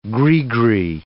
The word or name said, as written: gris-gris